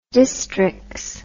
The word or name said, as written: distrix